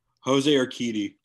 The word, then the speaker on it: Urquidy: Jose Urquidy.